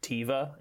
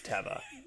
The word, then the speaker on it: Teva: And the brand of sandal is spelled T-E-V-A.